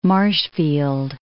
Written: Marshfield

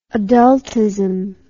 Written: adultism

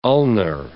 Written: ulnar

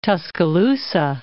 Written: Tuscaloosa

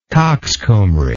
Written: coxcombry

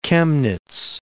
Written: Chemnitz

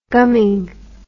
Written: gumming

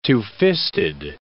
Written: two-fisted

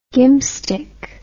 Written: gimpstick

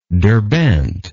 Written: derbent

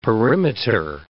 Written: perimeter

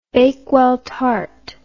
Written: bakewell tart

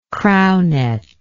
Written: crownet

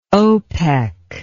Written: opec

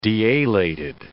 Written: dealated

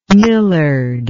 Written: millard

